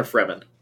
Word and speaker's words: Fremen: Is it Freeman or Fremen? – I say Fremen.